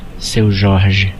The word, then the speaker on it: Seu Jorge: Seu Jorge.